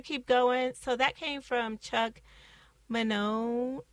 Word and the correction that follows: Mangione: Okay, I already messed up his name the first time, and I even did like a whole pronunciation, but I'm going to say it's Mangione.